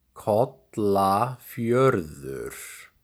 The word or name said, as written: Kollafjörður